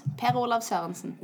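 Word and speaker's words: Per-Olav Sørensen: So his name is Per Olav Sorensen. – That's pretty close. Per Olav Sorensen.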